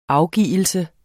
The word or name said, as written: afgivelse